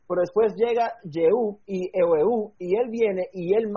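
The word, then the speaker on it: Jehú: ¿Cómo se dice Jehu en español? Ehu, yo quiero decir Ehu. Ehu. – Jehu. – Ehu, ok.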